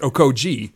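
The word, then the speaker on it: Okogie: I don't know if this is something that's always been in the media guide, but we noticed that there was a pronunciation guide in the media guide this year from the Timberwolves, and they recommended it's pronounced Okoji.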